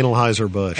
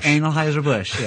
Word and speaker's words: Anheuser-Busch: Yeah, Anheuser-Busch. – Anheuser-Busch, yes.